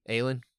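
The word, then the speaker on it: Ailín: Aileen Perez? – Aileen. – Okay, fair enough. She's Ailing without the G.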